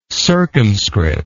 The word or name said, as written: circumscript